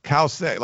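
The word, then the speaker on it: Cauce: Is it Kause or is it Couchy? – Kause. Like, I've looked up her pronunciation. They say Cow, C-O-W, dash S-A-Y. And it's C-A-U-C-E.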